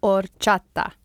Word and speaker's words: horchata: Quick pronunciation lesson. It's horchata. – Horchata. – Closer. Horchata.